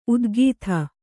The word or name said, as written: ಉತ್ಖಾತ